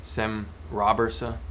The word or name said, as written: Sem Robberse